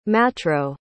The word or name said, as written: matro